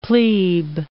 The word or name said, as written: plebe